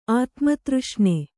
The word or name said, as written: ಆತ್ಮತೃಷ್ಣೆ